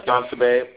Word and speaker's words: Danasabe: It's just John Sabe, Papa Midnight Muhammad.